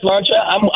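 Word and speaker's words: Sláinte: You said Slancha. I'm with you. It means health, which, of course, we all wish.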